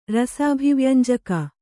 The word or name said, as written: ರಸಾಭಿವ್ಯಂಜಕ